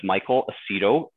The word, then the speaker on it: Michael Aceto: My name is Michael Aceto, A C E T O.